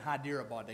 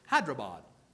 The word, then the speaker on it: Hyderabad: I got to say it right. I was saying Hyderabad. They correct me. Hyderabad. Excuse me.